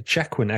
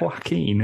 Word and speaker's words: Joaquin: Is Joaquin is a Jaquin actually like a Jaguar / Phoenix? – It's pronounced Joaquin, isn't it? – I think you're making that up. – No, it is. That's how it's pronounced.